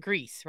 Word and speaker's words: Gries: uh john i think i'm saying this right Gries right uh you yeah.